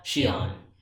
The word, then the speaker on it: Xion: Yes, and during this time as well, they're introduced to another organization member, and her name is Xion. Okay, and it's spelled X-I-O-N. So that's how they spell Xion.